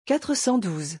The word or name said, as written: 412